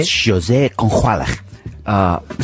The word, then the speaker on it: José González: We've already had terrific music, but in the rest of the hour, we've got some Smashing Pumpkins, some José González. Is that how you say it? – You say José. – José González. Some Modest Mouse.